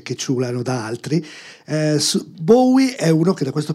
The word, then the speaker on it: Bowie: Ho visto che la prossima puntata è ancora su Bowie. La pronuncia è Bowie, non Bowie, please.